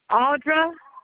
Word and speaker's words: Audra: Audra. – Can you spell that for me? – A-U-D-R-A.